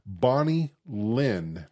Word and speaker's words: Bonnie Lynn: Bonnie Lynn.